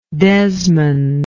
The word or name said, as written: Desmond